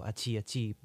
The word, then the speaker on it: Aci Aci: Try to pronounce it Aci with a two after it. So Aci Aci Buka Pintu.